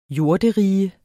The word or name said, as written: jorderige